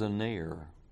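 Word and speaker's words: a ner (ἀνήρ): It is a ner. We would spell it A-N-E-R.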